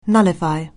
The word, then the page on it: nullify: anglais